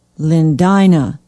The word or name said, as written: lindina